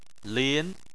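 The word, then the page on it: lin: tone 2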